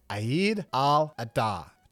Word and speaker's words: Eid al-Adha: The Muslim holiday she referred to should be pronounced Eid al-Adha.